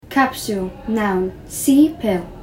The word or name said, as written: capsule